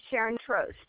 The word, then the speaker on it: Sharon Trost: This is Sharon Trost.